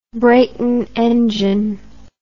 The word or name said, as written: Brayton engine